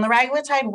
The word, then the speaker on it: liraglutide: Liraglutide. Is that correct? – So liraglutide. And this was liraglutide. I'm struggling. – Yeah. So this was a game changer for the weight loss medicine world. And liraglutide works as a GLP-1 agonist.